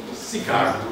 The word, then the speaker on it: Sychar: I used to call it Sychar because it's S-Y-C-H-A-R. But the Greeks speak, I mean Greekly. Greekly it would be Sychar. The U that's, in Greek it's like a U, an upsilon, which is pronounced kind of like a Y, and usually is transliterated into a Y in English, and has kind of an E sound.